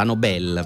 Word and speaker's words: Nobel: La Nobel dimenticata, la Nobel per essere precisi con l'accento,